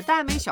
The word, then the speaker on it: 耽美: 经过女同事们的科普，我才知道，原来花城是耽美小说《天官赐福》的男主角。说到耽美小说，我这个直男完全不懂，甚至之前一度念成了梗美小说。